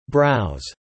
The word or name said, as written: browse